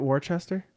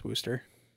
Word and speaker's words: Wooster: Isn't it Worcester? – No, it's Wooster. – It's Wooster? – It's Wooster. – That's really how it's pronounced? – Yeah, there's a Wooster College in Pennsylvania, I think, but it's W-O-O-S-T-E-R. But, yeah, no, they pronounce it Wooster.